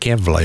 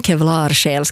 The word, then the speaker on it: Kevlarsjäl: Jag säger alltid Kevla, ja. Men svenskarna ska alltid göra det svårt. – Jo, men det är för att alla känner Kent-låten och där man har det uttalet på det. Men Kevlarsjäl ska det tydligen språkligt heta.